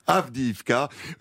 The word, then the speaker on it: Avdiivka: Alors nous sommes dans une petite commune, hein, est-ce que j'ai bien prononcé, Avdiivka, 35 000 habitants, allez-y, prononcez-la. – Avdiivka,